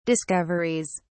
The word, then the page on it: discoveries: British English